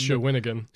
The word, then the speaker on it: Shawinigan: That's, that's It's Shawinigan. – Oh, Shawinigan. that sounds way more Canadian than Shawnigan.